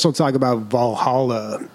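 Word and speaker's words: VA-11 HALL-A: This game is called Valhalla, but it's spelled with ones and, VA-11 space HALL-A.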